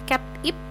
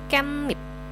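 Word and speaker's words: kenip (깻잎): Jadinya dibacanya bukan cap ip melainkan kenip.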